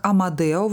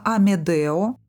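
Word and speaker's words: Amedeo: Кто-то произносит его имя как Амадео, вообще пишется Амедео. Можно по-разному, да, как вам угодно.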